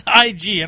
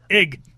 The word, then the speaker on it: Ig: It's IG. I don't know. How do you say that? Ig. – Ig. – Ig. – Sounds like an exclaim that someone would say when they get punched in the stomach.